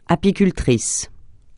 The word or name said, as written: apicultrice